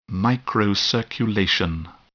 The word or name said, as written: microcirculation